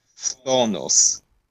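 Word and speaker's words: ftonos (φθόνος): a to słowo jest trudne do wymówienia, bo są dwie, obok siebie dwie spółgłoski, to się wymawia ftonos, czyli zawiść albo zazdrość.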